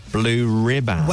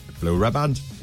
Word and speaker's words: blue riband: The team there pronounce it like Pippa. Blue ribbons. – Where was the factory? – Newcastle. – Oh, well, then there you go. That's the accent, isn't it? – That's it. Blue riband.